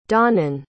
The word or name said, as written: donnan